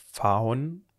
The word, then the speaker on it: Phaon: namens Faon oder Paon, also ich würde P-H-A-O-N, Faon würde ich es aussprechen.